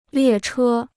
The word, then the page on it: 列车: liè chē